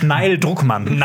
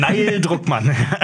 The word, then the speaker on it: Druckmann: Spricht man den Druckmann aus? Weil das klingt, das wäre ein Dealer oder sowas. heißt Neil Druckmann. Neil Druckmann.